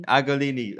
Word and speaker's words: Agolini: Agolini.